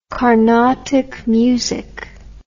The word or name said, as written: karnatak music